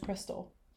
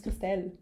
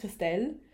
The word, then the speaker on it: Christelle: Raum sage ich immer, Hi, my name's Crystal. My name's not Crystal. If I go by what I learned and how I say my name, my name's Christelle. Mhm. Und da gibt es das englische R nicht.